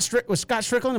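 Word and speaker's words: Stricklin: I did see where, was it Scott Strickland? Am I saying that right? He's the AD at Florida, right? – Yes.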